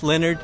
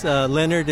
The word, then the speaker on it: Leonard: My name is Leonard Higgins. Leonard is L-E-O-N-A-R-D, Higgins, H-I-G-G-I-N-S.